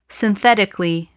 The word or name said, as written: synthetically